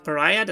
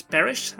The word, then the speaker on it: Parish: That's not Pariah, that's Parish. I said it incorrectly last time, and I'm so sorry.